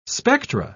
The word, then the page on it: spectra: British English